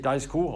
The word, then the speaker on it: Daisuke: In einem sitzt der ehemalige Konzernchef Morizo, sprich also Akio Toyoda, im zweiten dessen Sohn Daiku, er spricht sich tatsächlich Daisuke und nicht Daisuke, sondern Daisuke,